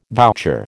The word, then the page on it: voucher: American English